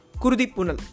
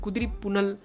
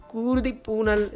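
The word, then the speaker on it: குருதிப்புனல்: action king Arjun directed by PC Sriram and it is Kurdi Punal yes I said it correctly because I have messed up this name so many times I've said Kudri Punal குருதி பூனல் என்னெல்லாமோ சொல்லியிருக்கேன் நான் எஸ் ஐ காட் இட் குருதி புனல்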